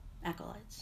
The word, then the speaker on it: acolytes: Acolytes. Acolytes. Yeah. Hard C, like Latin.